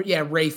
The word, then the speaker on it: Ralph: It says pronounced R-A-Y-F Fiennes. – Yeah, Ralph Fiennes.